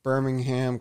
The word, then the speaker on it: Birmingham: London and Birmingham collections revealed. And the H isn't pronounced in Birmingham. It's just kind of silent.